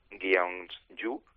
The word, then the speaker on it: Gyeongju: Gyeongju.